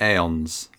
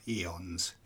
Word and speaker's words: aeons: And from out of the aeons. – Eons. It's pronounced fucking eons.